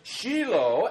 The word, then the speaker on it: Shiloh: Scholars will tell us that the word that's translated Shiloh, you know, we're used to saying it with a long I sound. The Hebrew would have said it a little bit different, Shiloh, and it means, according to scholars, he to whom it belongs.